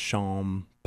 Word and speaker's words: Champagne: But I always tell people it's just like S-H-O-M-P-I-N-E, just Champagne. Pine.